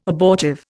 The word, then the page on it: abortive: Indian